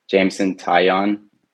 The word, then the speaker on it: Jameson Taillon: Yankees have Jameson Taillon. – Yes. – If I'm pronouncing that correctly. – Jameson Taillon.